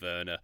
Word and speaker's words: Werner: Nothing rhymes with Werner. Lerner. There was one. Burner, Joe, lots of things rhyme with Werner.